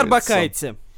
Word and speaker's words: Орбакайте: Арбакайте. Арбакайте. Да, О, а как блин. мы недавно узнали, оказывается, правильно говорить арбакайте. По крайней мере, по версии самой Кристины.